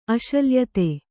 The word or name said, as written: ಅಶಲ್ಯತೆ